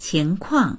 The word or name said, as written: qing2kuang4